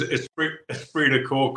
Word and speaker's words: esprit de corps: Esprit de corps. Is that did I pronounce that right? – Was that, no, was that's that French. We don't use French in the British Army. No, you're right. It is esprit de corps, comradeship, whatever, band of brothers.